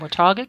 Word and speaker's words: Watauga: In Watauga County. – Watauga County.